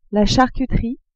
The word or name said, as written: charcuterie